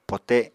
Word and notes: pote: apatani

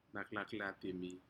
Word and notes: naklakla adimi: Aneityum

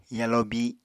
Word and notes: yalo bi: Apatani

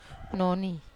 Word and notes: noni: wamesa

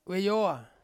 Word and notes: weyoa: Ansus

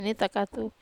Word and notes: neta katu: Wamesa

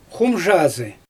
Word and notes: xumʒazɯ: Xyzyl

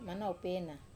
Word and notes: manau pena: wamesa

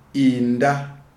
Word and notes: Inda: Shona

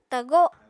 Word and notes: tago7: Apatani

